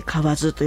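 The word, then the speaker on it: 蛙: 河津ってね、これカエルと書いて河津と読むんですけれどもね、中学生の頃ね、これカエルじゃないぞ、河津って読むんだぞってね、国語で習いましたけれども、意味は古池にカエルが飛び込んだ水の音が聞こえるほど静かだということで、静かなことをね、表している情緒たっぷりの一句となっていますね。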